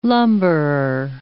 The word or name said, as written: lumberer